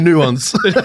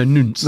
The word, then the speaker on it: nonce: It's a nuance. – No, nunce. No.